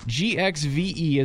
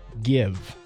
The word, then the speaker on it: GXVE: GXVE is the name, and it's pronounced like give.